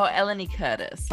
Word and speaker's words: Eleni Curtis: Alani Curtis? I can never say your last name. Is that how you pronounce it? – Oh, Eleni Curtis. Like Curtis. – Maybe it's an accent, but Curtis. Yeah, Curtis.